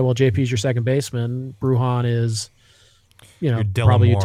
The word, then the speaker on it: Bruján: I think, by the way, it's pronounced Bruján. – So. Yeah, it's Bruján.